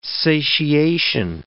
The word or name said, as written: satiation